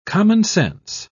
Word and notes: commonsense: British English

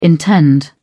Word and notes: intend: British English

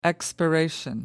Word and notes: expiration: British English

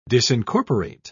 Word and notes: disincorporate: British English

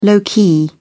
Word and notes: low-key: British English

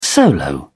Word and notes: solo: British English

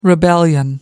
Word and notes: rebellion: American English

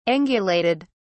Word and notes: angulated: British English

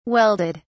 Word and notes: welded: British English

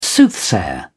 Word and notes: soothsayer: British English

